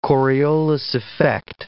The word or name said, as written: Coriolis effect